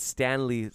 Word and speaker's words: Sctanley: Okay, it's Stanley, spelled with a C and an EY at the end. So it's on IMDb, it's spelled S-C-T-A-N-L-E-Y. – Stanley.